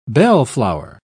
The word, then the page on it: bellflower: British English